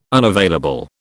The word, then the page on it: unavailable: British English